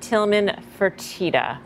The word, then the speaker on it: Tillman Fertitta: Let the record show that you pronounce it Tillman Fertitta.